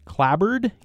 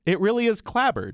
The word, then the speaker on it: clapboard: So it really is clabbered. – It is clabbered, and it's been pronounced that way since the early 16 or 1700s.